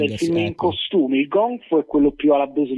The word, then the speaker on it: Gong Fu: Wuxpian, ho detto bene? – Si chiama Gong Fu. – Ah, Gong Fu.